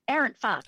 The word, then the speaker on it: Arendt Fox: How do we pronounce the firm name? – Arendt Fox.